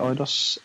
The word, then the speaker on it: Eidos: Is that how you pronounce it, Eidos? And that's how I pronounce it.